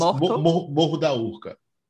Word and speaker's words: Morro da Urca: And then another thing that I think about is, like, you know, meeting up with friends, like Morro da Urca. I think I butchered that pronunciation. – That's perfect. It's Morro da Urca.